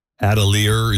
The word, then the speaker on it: Atelier: And I guess they're, you know, promoting the launch of her fashion house, Atelier. Is that how you pronounce it? – I believe so, yeah.